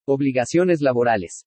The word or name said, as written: OBLIGACIONES LABORALES